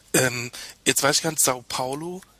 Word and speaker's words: São Paulo: ich richtig wäre eigentlich mit der schönen Cedilla über dem, über dem A in Sao Paulo. – Sao Ganz äh, Paulo. sanft nach, genau so nach vorne ausgesprochen.